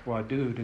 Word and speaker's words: wadud: Even though it's pronounced wadud, it's spelled vav dal, W-D-W-D. It's like whirling. W-D-W-D, W-D, wadud, wadud, wadud.